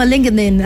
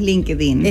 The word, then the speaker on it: LinkedIn: Sí, bueno, en Estados Unidos se pronuncia diferente, es como LinkedIn, así como... – LinkedIn. – Exacto, LinkedIn, sí. Y acá en Chile en general le decimos LinkedIn.